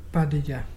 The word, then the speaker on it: barraja: That is spelled B-A-R-R-A-J-A, but pronounced Padella.